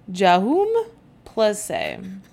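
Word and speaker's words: Jaume Plensa: And it's by the artist Jahoum Plesse, which is exactly how you pronounce it.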